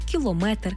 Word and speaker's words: кілометр: В іншомовних словах, які позначають міри довжин, наголос падає на метр. Тобто кілометр, сантиметр, міліметр, дециметр.